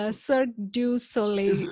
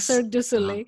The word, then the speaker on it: Cirque du Soleil: So you have worked with some big names, like, I don't know how to pronounce this, but Cirque du Soleil. – Yeah, Cirque du Soleil, Google, Uber, Microsoft, and many more.